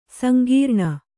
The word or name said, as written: ಸಂಗ್ರಾಣ